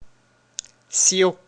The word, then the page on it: seau: France (Paris); [ɛ̃ so]